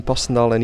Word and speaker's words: Passendale: We zitten bij u in het Passendale Memorial Museum, zeg ik dat goed? – In het Memorial Museum Passendale 1917.